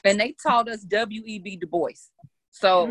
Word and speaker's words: Du Bois: Oh, for y'all that don't know, it's not W.E.B. Du Bois. It's Dubois. We should say his name right.